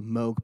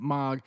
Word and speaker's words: Moog: It's another keyboard, but it's doing what the bass is supposed to do, and that's called the Moog bass, the M-O-O-G. Some pronounce it Moog, some pronounce it Moog, some pronounce it Mog.